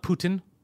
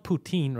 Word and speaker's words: poutine: It's poutine. It's P-O-U-T-I-N-E. Poutine. Not Putin. Not Putang. Poutine.